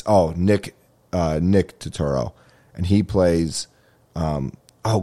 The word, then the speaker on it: Turturro: Did I say that right, Turturro? Yeah.